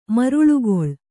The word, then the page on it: ಮರುಳುಗೊಳ್: maruḷugoḷ; kannada